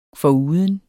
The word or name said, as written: foruden